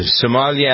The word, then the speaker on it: sommelier: And then there is you being a sommelier.